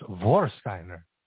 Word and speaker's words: Vorsteiner: It's pronounced Vorsteiner, like Vakken.